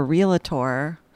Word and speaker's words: realtor: You're also a realtor. – It's only two syllables in realtor.